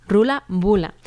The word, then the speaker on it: Ruaille Buaille: Vamos desde Cantabria un poco hacia el este para llegar hasta el País Vasco, para escuchar al grupo Rula Bula. El nombre se escribe un poquito diferente, es Ruaille Buaille, que quiere decir juerga, alborozo, en irlandés.